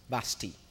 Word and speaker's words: Vashti: That was a queen before Esther, and it's pronounced, it is spelled V-A-S-H-T-I, but it's actually pronounced Vashti, because the T, it's not the T, the I is silent, and in the Hebrew is Vashti.